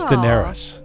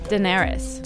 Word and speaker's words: Daenerys: I think it's Daenerys. – Daenerys. – Did you not watch Game of Thrones? – Daenerys. I did, but it's been so long. – It was Daenerys.